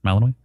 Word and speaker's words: Malinois: Malinois.